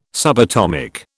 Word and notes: subatomic: British English

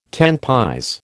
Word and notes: tant pis: American English

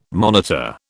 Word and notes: monitor: British English

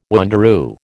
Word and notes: wanderoo: American English

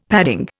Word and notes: padding: Indian

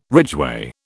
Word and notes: ridgeway: British English

